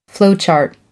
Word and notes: flowchart: American English

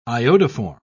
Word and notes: iodoform: British English

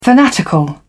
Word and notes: fanatical: British English

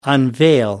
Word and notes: unveil: American English

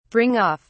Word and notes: bring off: British English

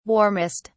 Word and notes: warmest: British English

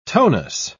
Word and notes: tonus: British English

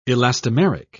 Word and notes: elastomeric: British English

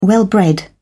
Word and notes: well bred: British English

